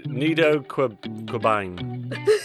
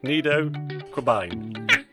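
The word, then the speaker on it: Nido Qubein: Nido Quibin. Q-U-B-E-I-N.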